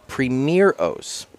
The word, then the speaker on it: primeros: And he informed me that it, the word actually is pronounced primeros, Primeros. and it's uh, Spanish for firsts.